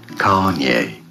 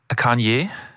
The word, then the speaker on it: Kanye: Kanye. – Kanye, finde ich, das sagst du super. – Ich glaube, die Betonung ist auf der ersten Silbe.